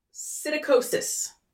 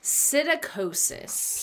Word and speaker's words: psittacosis: Psittacosis. Starts with a silent P. – Ooh, I love silent P's. – I know. – Psittacosis.